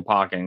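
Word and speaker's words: Pokkén: Well, Pokemon World Championship, but no, like, you know, Pokken is just like a different thing, or Pokken, excuse me.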